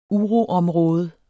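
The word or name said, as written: uroområde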